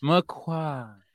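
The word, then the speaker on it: McQuoid: I believe there's a lot of ado about how her last name is pronounced in the I find that if you just quiet down as you get to the end of it, you'll probably hit like most of it properly. Yeah. McQuad.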